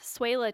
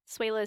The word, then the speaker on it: Swehla: You can find me on Twitter at Swela Tessa. Swela is spelled SWEHLA, and you can find me on Letterboxd under the same name.